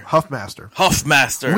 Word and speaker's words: Huffmaster: Berlin Hoffmaster. – Huffmaster. We kept saying his name wrong. His name's Huffmaster. It's a U. – I kept saying Huffmeyer.